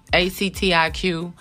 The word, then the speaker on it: Actiq: In its prescription form, fentanyl is known by such name as Duragesic, Sublimase, ATIC. I think that's how you pronounce it. It's ACTIQ. ACTIQ.